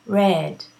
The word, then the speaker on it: read: R-E-A-D. Read. The spelling is the same as the spelling of the present. I read, okay? But the pronunciation of this verb in the past simple is read, like the color, okay?